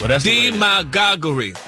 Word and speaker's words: demagoguery: Demagoguery. – That's the way the dictionary broke it down. – The pronunciation, like the emphasis, is on the D.